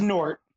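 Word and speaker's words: Gnort: This is Nort. You don't say the G. – Yeah, right? I was wondering that. – So how much do you know about good old Nort? – How much?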